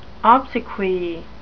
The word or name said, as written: obsequy